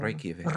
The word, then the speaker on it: Reykjavik: Well, you have to roll your R's a little. – Reykjavik. – Reykjavik.